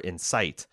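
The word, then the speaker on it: Incite: It's subtle, but we tend to put the emphasis on the first syllable for Insight versus the second for Insight.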